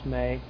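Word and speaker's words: may (μή): It's pronounced may. In English, we would write it as M-E.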